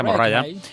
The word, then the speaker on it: Mariah: Pues ya para terminar, diremos que María Mariah Carey, que es como se pronuncia Mariah, y su catastrófico playback en Jamaica.